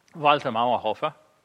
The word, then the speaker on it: Mauerhofer: A man named Walter Mauerhofer, let me say it like a good German, Walter Mauerhofer, is actually from Switzerland.